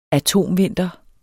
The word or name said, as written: atomvinter